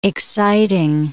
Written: exciting

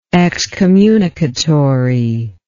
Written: excommunicatory

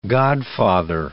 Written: godfather

